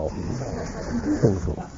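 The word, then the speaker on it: Fionn mac Cumhaill: His uncle Finn McCool, you might want to write down the name Finn McCool, you spell it just as it sounds. F-I-O-N-N-E is Finn. And McCool is M-A-C-C-U-M-H-A-I-L. Finn McCool. Finn was about to marry his 98th wife or something of the kind, and her name was Griana, G-R-I-A-N-N-E, or Grianny.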